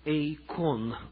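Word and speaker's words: эйкон (εἰκών): Древнегреческое слово «эйкон» – ударение на второй слог.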